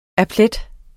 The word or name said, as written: applet